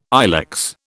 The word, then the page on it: ilex: British English